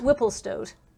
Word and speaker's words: Whipplestoat: Whipplestoat. – Stoat. – Stoat. – Stoat or stout? – Stoat. – Like the rodent? – Yeah.